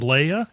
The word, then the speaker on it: Leia: And that's why I need you to pronounce this princess's name, because I'm not sure how it's pronounced. – You mean Leia? – Is that her? – Yes.